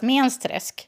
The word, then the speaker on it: Mensträsk: Fast som ju uttalas Mensträsk. Men du måste säga. – Mensträsk. Men du måste säga det med Mensträsk. – Så att det är lite nasalt. – Jo, lite nasalt.